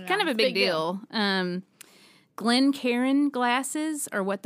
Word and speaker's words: Glencairn: And that is spelled GlenCairn. So it's pronounced Glen Karen.